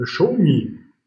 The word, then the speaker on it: Xiaomi: Bitcoin steht vor dem Allzeithoch, Anheuser Busch ist ein Thema und Xiaomi, richtig ausgesprochen, am Automarkt.